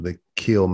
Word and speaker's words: Kiln: Now, it's spelled K-I-L-N, just like the town is spelled K-I-L-N, but they pronounce it the Kill. They don't call it the Kiln or Kiln. – It's not Kiln.